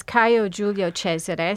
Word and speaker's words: Caio Giulio Cesare: Yes, it's Caio Giulio Cesare, so Julius Caesar.